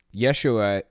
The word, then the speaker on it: Yeshua: Yeshua is actually the original Hebrew pronunciation of Jesus. J's didn't even exist until like the 1600s or something like that. So I find it really weird that we use Jesus at all. It's Yeshua, not Jesus.